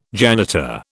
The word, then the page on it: janitor: British English